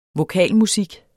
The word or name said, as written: vokalmusik